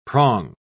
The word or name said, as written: prong